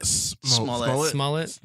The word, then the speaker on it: Smollett: Smollett.